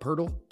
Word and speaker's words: Poeltl: Jakob Poeltl? – Thank you. – I got you. – Yeah, they got Poeltl, and then the Spurs got Kaheem Birch, 2024 first round, and two second round picks for 23 and 25.